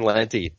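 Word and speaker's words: Lente: Ivar Timewalker, number 10, writer Fred Van Lent, let's see if I can pronounce this right. Lenti? – Fred Van Lenti.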